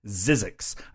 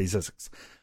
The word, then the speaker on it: Zzyzzyxx: I would hereby like to request that you review the 1982 Cinematronics game Zizzix. I think I'm pronouncing that right. It's ZZYZZYXX. I'm going to say Zizzix.